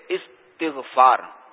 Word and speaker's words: استغفار: ہاں, اس میں یہ ہے کہ یہ جو صحیح تلفظ ہے نا, یہ استغفار, استقبال, استغفار, اس کے وزن پر استقبال کے وزن پر,